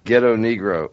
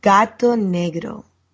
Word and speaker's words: Gato Negro: They messaged me back and they're like, you said ghetto Negro. They said says it right. So how do I say it? – You say gato negro.